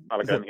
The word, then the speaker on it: Balogun: They have Ryan as your goalkeeper, Bong, Balogun. Did I say that correctly? – Balogun, yeah. Balogun, yeah.